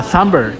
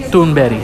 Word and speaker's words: Thunberg: Un día hablaré más eh, profundamente de ella, pero bueno, se llama, eh, si vosotros lo leéis, su nombre, vais a decir Greta Thunberg. Pues no, no se llama Greta Thunberg. Bueno, eh, si fuera inglesa o americana, se, se pronunciaría así, pero se ve que esta chica es sueca y su nombre se pronuncia Greta uh, No Greta, Greta Thunberg, sino Thunberry.